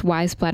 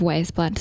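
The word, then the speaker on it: Weyes Blood: Forgive me, I pronounced her name wrong within the interview because I didn't learn that it's actually pronounced Wise Blood instead of Waze Blood, so you'll just have to bear with me on that mistake.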